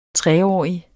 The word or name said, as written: treårig